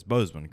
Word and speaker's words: Boseman: Is it Boseman or Boseman? It's Boseman, correct? Boseman. Okay, so I'll make sure Boseman.